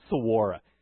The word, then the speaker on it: saguaro: I used to say saguaro cactus, but I think it's saguaro.